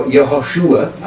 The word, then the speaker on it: Yehoshua: Either Yeshua or Yehoshua, either one, if you want to get the Hebrew pronunciation right.